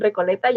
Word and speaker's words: Recoleta: Club de Mujeres de Poesía de, Argentina inició en Recoleta, ya me confirmó Germán de que así se pronuncia.